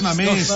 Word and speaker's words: Mamés: O el otro es no mames. Ay, mamés. – Mamés. – Un acento.